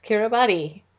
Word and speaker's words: Kiribati: Even though it's spelled K-I-R-I-B-A-T-I, it's not pronounced Kiribati. It's pronounced Kiribati.